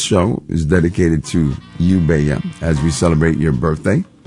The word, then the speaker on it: Beya: And we named her Beya, but we spell her name B-E-Y-A because if we spelled it in Spanish, B-E-L-L-A, people would most likely pronounce it Bella. We want to make sure they pronounce it Beya. Beautiful, B-E-Y-A.